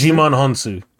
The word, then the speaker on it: Djimon Hounsou: And Djimon Hounsou, is that how you Jim- pronounce Djimon Hounsou.